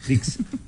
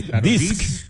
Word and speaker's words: Disc: Hoy, un día como hoy, Sony lanzaba su primer lector de Compact Disc. – ¿De quién? – Del Compact Disc. – Disc. – Dix. En Paraguay. – No, espera. Disc. – Claro, de disco.